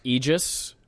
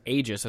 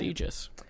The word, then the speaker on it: aegis: It is pronounced Aegis, although Merriam-Webster lists Aegis as a secondary pronunciation. Ooh, but it is Aegis.